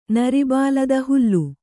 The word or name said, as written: ನರಿಬಾಲದ ಹುಲ್ಲು